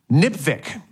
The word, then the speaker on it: NPVIC: A way to pronounce NPVIC, NIPVIC, which it rhymes with lipstick.